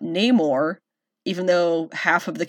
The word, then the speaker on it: Namor: El niño sin amor. Namor. There you go. Say it right!